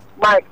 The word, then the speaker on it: Mike: Mike. – Please, can you spell it? – Mike from Yanaimaja. – Okay. Ima Abi Max. – Mike, M-I-K-E.